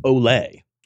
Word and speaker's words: Ole: I believe it is pronounced Ole. – Oh, that's true. Ole Anderson.